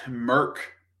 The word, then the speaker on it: Merklinger: Let's dive in and talk about Jake the Snake Merklinger. Is that how you say it? – Merk is what I mean. There's a K.